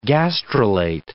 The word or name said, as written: gastrulate